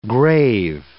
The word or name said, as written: grave